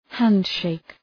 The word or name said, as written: handshake